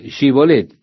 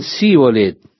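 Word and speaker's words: Shibolet (שיבולת): Luego les hacían decir Shibolet, y los hombres de Efraín no podían pronunciar la SH, y dijeron Sibolet.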